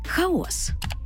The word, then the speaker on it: хаос: Хаос чи хаос? Хаос із наголосом на перший склад. У міфології це безмежний світовий простір. Суміш усіх стихій, безодня, з якої витворилося усе. Первісний хаос. Натомість відсутність порядку, безлад, плутанина, гармидер – ось це хаос.